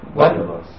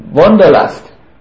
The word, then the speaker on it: Wanderlust: Wanderlust. – And you say it like an American sound, with American sounding. Wanderlust.